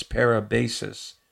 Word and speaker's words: parabasis: The Greek word there is parabasis, or maybe pronounced difference, but it's P-A-R-A-B-A-S-I-S.